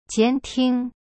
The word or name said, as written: 监听